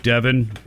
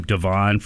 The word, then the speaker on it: Devin: I have to remind myself, Devin rhyming with Kevin, but they couldn't spell it that way, of course. I was calling him Devon for the last couple of years because that's how the name is spelled.